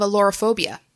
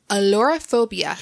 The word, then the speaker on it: ailurophobia: Napoleon Bonaparte was known to have alorophobia. This is the fear of what common domestic animal? – Say the word again slowly. – Alorophobia. – Could you spell it, please? – A-I-L-U-R-O. P-H-O-B-I-A.